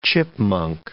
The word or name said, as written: chipmunk